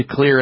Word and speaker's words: Xlear: Time to clear it out with a nasal spray called Clear, X-L-E-A-R, Max Nasal Spray, a fast way to help reduce allergy symptoms. Clear Max Nasal Spray. Jot that down, and Clear is spelled with an X, X-L-E-A-R.